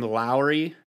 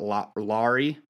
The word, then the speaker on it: Lauri: Now, Lauri Markkanen, I got to start saying his name Lowry because that's how you say it, not Lauri.